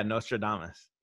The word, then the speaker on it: Nostradamus: And what she found, there was like a group, basically, first of all, there was, is it Nostradamus? – No. Yeah, the prediction guy or whatever. – Yeah. Am I saying it right? Right. – Yeah. Nostradamus.